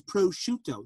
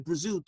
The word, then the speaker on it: prosciutto: They're the one that goes, no, it's prosciutto. No. That's a different word. Your grandma said Brazut when she was living on Bloomfield Avenue in Newark, because that's the Neapolitan pronunciation.